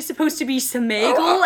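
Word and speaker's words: Sméagol: And what's interesting about that is that's actually how it's pronounced. – Who what? – Smeagol. If you read it, there's like an accent mark. – Oh, no. – In the book. And I think even the Peter Jackson like whole production has acknowledged that it is properly pronounced Smeagol.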